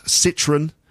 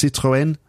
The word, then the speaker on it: Citroën: So we talk about Renault, we talk about Citroën. In French, it's Citroën, and Peugeot, which I still find difficult to pronounce in French.